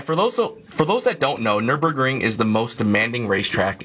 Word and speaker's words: Nürburgring: And the Nürburgring record, I said that right, Nürburgring? – Yeah, okay, Nürburgring.